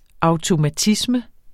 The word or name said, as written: automatisme